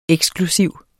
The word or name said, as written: eksklusiv